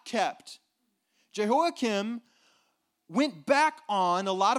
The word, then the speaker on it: Jehoiakim: Jehoiakim, right? And I, I think I'm pronouncing that correctly. Jehoiakim. P. Sid, can, is that correct? Okay. Jehoiakim, who killed, this is, this is one of the things he's known for.